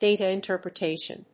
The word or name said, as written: data interpretation